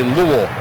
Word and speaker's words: Louisville: So the way you say Louisville is you just say it, and then you kind of stop saying it. You say Louisville. You just stop saying Louisville. – It. Louisville. – Louisville. – Louisville. Kind of like one syllable.